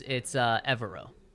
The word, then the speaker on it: Evero: You may not be pronouncing Mr. Evero's name correctly. In the Get to Know Defensive Coordinator video posted on the Broncos YouTube account, he pronounced it by putting the accent on the second syllable or the second E.